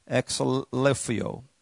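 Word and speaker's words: exaleiphō: I'll spell it for you, then I'll pronounce it. EXALEIPHO. EXALEIPHO. Exhalifio.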